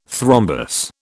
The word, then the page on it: thrombus: British English